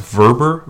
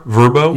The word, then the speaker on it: Vrbo: Do you know it's pronounced Verber? Verbo? – Yes.